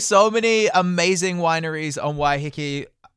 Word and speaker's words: Waiheke: Okay, so because I'm a trashy wine drinker, I'd really like to go to Waiheke Island. Is that, have I said that correctly? – Yeah. Waiheke? – Waiheke? – Yeah.